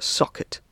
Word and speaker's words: SCKT: It's called SCKT, which Jonathan has told me can also be pronounced socket, so that rolls a little bit more easily off the tongue.